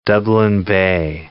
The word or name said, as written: Dublin Bay prawn